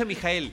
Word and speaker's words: Michael: No, no, no, bueno, pero se pronuncia Mijael.